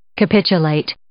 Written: capitulate

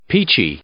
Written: peachy

